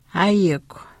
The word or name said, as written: aayikw